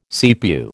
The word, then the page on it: CPU: American English